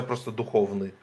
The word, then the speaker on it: Духовный: Как его имя произносится? Духовный или Духовный? – Я вот без и краткого в конце, то есть я просто Духовный говорю.